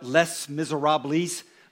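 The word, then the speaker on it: Les Misérables: It's on Les Miserables, which, yes, I know it's spelled Les Miserables, okay? But it's French. They dropped the last syllable. I don't know why.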